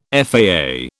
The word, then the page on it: FAA: British English